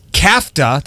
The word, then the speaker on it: kafta: Kafta, did I say that right? – Yep.